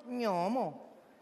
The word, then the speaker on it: gnomo: No, mejor un gnomo. – Gnomo. Gnomo. Se pronuncia gnomo. ¿Cómo? Con G primero.